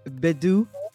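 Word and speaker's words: Mbedu: I know to pronounce her last name is Bedu.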